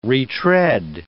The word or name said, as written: re-tread